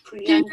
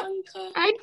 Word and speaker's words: Priyanka: Priyanka. – You Priyanka. – it twice. It said it twice, and she gagged over it. – That's great. – Okay, just be quiet, okay? – Priyanka Chopra.